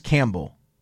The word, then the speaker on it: Campbell: Do they just say, okay, the name of the school is Campbell? Camel rhymes with Campbell, so that's what we're going with? – Yeah, it's kind of cool.